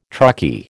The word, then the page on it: truckie: American English